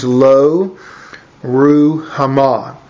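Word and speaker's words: Lo-Ruhamah: And God said to him, I want you to name that daughter Lo Ruhamah. That's a little more the way you pronounce it in Hebrew. It means Lo Ruhamah.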